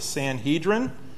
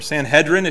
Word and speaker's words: Sanhedrin: We may not always describe what it is, but it's the Sanhedrin, or Sanhedrin, if you like that pronunciation.